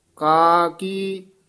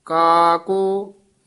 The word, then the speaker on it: का की: गौड़ी महला पंजवा, का की माई का को बाप। ये भी पाठ ध्यान नाल उच्चारण करना है। काकी माई काको बाप नहीं कहना, का की जिहड़ा पाठ है ये संबंधक है, लमका के बोलना है।